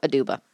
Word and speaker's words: Aduba: Abuda. No, Aduba. Sorry. Sometimes I get B's and D's confused.